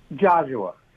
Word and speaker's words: Geozuwa: Geozuwa.